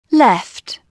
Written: left

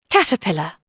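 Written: caterpillar

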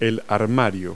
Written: closet

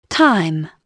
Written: time

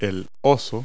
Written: bear

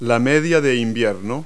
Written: tights

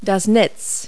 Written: web

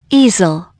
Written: ease

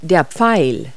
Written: arrow

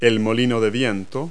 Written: windmill

